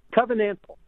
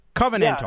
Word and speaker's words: covenantal: You write, we are driven by what I call a conventional reason. – Covenantal. – Covenantal. Yeah, that's how you pronounce it.